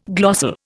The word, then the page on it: glossal: Indian